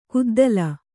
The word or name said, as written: ಕುದ್ದಲ